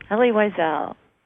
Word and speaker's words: Wiesel: No, not Wiesel. – Wiesel. – Ah, oh, Wiesel. Thank you. – Elie Wiesel.